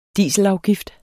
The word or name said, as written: dieselafgift